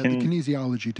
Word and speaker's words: kinesiology: It's that and I always mispronounce it. It's like Kinesian tape or the kinesiology tape.